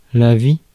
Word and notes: life: /laɪf/

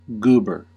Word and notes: goober: American English; /ˈɡuːbɚ/

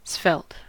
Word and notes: svelte: American English; [ˈsvɛlt]

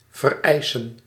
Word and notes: vereisen: /vɛrˈɛɪsə(n)/

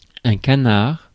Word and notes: canard: [æ̃ ka.naːʁ̥]; [ka.naʁ̥]; /ka.naʁ/

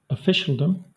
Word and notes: officialdom: Southern England; /əˈfɪ.ʃəl.dəm/

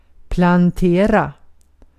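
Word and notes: ekmek: Turkish

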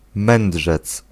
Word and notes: wijze: [ʋɛj.zə]

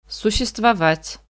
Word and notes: существовать: /sʊɕːɪstvɐˈvatʲ/; suštšestvovat

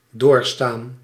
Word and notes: subir: France; [sy.biʁ]

